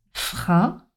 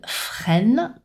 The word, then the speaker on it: frein: Attention à ne pas prononcer le N, le son N au masculin. Ne pas dire frein, freine, non. Frein, c'est un son de voyelle nasale. Petite exception intéressante dans la langue française, le blanc seing, donc qui s'écrit E-I-N-G, on ne prononce ni le N ni le G.